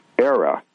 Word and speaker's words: AEHRA: Okay an Italian company spelled A E H R A, pronounced ERA, has unveiled its electric cars, which they are going to be taking orders for in 2024 for delivery in 2026.